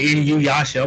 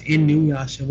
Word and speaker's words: Inuyasha: My mom would say Inuyasha. I'm like, Mom, it's Inuyasha. Where are you getting the extra Y from?